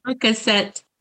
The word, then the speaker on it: Percocet: Percocet.